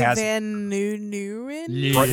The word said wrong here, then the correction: Van Leeuwen: I don't know how to pronounce his name, but yeah, Troy Van Leeuwen, who played in Failure.